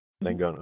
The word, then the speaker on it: Langone: Is it pronounced Langone or Langone or Langone? – Langone. Okay.